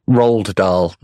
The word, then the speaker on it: Roald: Where he, he tells kids, Ronald Dahl, the author. Yeah. Roald, yeah. What? Did I say his name wrong? What yeah. is it? – Roald Dahl, not Ronald Dahl.